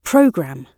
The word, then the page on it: programme: British English